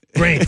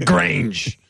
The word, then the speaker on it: Grange: Go ahead, some vindaloo. Grange. We just go with the Grange. Got to emphasize the G.